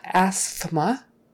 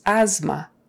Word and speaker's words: asthma: So asthma, it's spelled A-S-T-H. How do we say it? With an A-Z. Why? Just because. So it's not asthma, like you might think just by looking at it. It's asthma. Asthma. A-Z-M-A, pretend. Asthma.